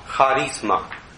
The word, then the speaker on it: charisma: But you write with C, but you pronounce it ha, charisma.